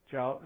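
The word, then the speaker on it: Chau: C C-H-A-U. Does anybody know? Chow.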